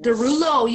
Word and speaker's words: Derulo: Derulo.